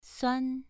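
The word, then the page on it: suan: tone 2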